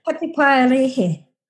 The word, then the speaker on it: Patupairahi: The Patupairahi? – Good job. Patupairahi.